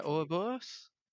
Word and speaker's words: Ouroboros: He's releasing a role-playing game called Ouroboros. I'm not sure how to pronounce it. – Yes, anyway. – You did pronounce it correctly.